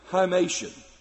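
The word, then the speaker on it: himation: Now the interesting thing is, the word for garment is the word, you and I would pronounce it, hymation.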